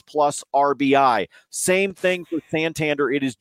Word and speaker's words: Santander: Anthony Santander. Is that how you say his name? Because I always want to say Salamander. – Salamander, Santander, sure.